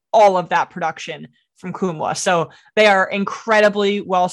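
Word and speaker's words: Nkamhoua: Olivier Kumwa, not Nkumwa. The N is... – the silent the N. Yes.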